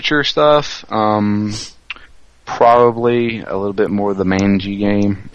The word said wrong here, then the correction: Majin: Is it Manji? I thought it was Majin. – It's pronounced Majin.